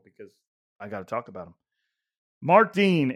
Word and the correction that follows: Dane: it's pronounced... No, Dane. Mark Dane, it's pronounced like Dane.